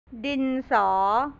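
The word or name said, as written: ดินสอ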